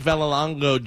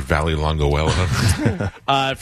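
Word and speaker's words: Vallelonga: Frank Villalonga Jr. Frank Villalonga. I love when they mispronounce the name a bunch of times in the movie. Listen to me, Mr. Valley Longuella.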